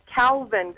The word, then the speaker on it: Cowvin: It's spelled C-O-W-V-I-N, like as in cows.